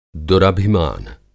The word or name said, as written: दुरभिमान